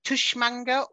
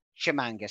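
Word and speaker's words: Tshimanga: But whilst we're talking about pronunciations, can I just ask, Tushmanga or Shimanga? Is it a silent T? – I'd go Shimanga. – Ben? – I feel like I just said Tishmanga. I think it should be Shimanga. – Shimanga, silent T.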